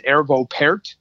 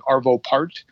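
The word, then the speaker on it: Arvo Pärt: like, but it's just like the best example I can sort of think of that is there's a classical composer named, I believe the way you pronounce his name is Ervo Pärt, but it looks like Arvo Part.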